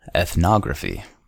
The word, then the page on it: ethnography: American English